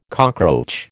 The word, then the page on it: cockroach: American English